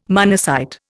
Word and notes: monocyte: Indian

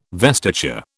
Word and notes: vestiture: British English

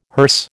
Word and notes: hearse: American English

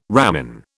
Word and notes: ramin: British English

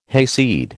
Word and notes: hayseed: American English

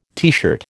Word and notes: teeshirt: American English

